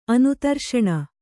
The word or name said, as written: ಅನುತರ್ಷಣ